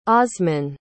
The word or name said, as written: osmin